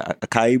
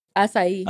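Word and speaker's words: açaí: Got the little, how you say it? Acai bowl? – Acai.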